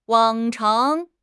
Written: 往常

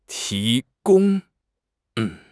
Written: ti2 gong1

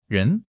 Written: ren2

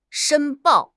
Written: 申报

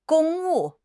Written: 公务

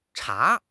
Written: cha2

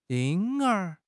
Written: 婴儿

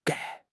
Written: 搁

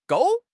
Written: gou4

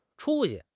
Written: 出息

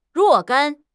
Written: 若干

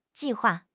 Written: ji4 hua4

